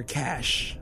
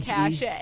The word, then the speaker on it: cache: So when you search online on your browser, make sure that you delete your catch completely, because pronounce catch. – Your cache. I think that's how the C A C. – I say cache.